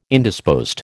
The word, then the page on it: Indisposed: American English